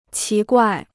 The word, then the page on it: 奇怪: qí guài